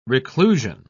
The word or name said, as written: reclusion